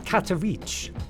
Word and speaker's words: Katowice: Big Band, Katowice. I looked that up on YouTube to find out how to pronounce it properly.